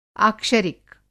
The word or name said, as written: आक्षरिक